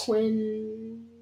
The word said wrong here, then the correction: Qui-Gon Jinn: Qui-Gon Jinn. – Yeah, Qui-Gon Jinn. I got your back. Qui-Gon Jinn. – I don't know how to pronounce his name. I thought his name was Quinn.